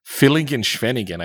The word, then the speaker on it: Villingen-Schwenningen: So that brought us to the south of Germany, to a Villingen Schwenningen, I think, was in the Black Forest.